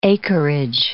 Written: acreage

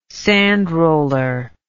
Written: sandroller

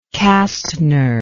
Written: kastner

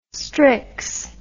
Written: strix

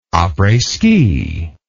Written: apres ski

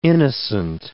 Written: innocent

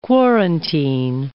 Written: quarantine

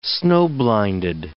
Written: snow-blinded

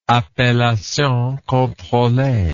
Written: appellation controlee

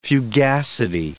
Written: fugacity

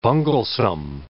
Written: bunglesome

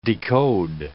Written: decode

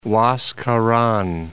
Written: Huascaran

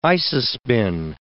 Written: isospin

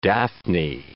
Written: Daphne

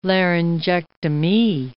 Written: laryngectomee

